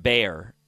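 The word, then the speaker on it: Gbayor: Nebraska linebacker Makai Bayer, or Gbayer. It's G-B-A-Y-O-R, but I think the G is silent. Makai Bayer.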